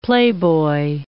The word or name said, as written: playboy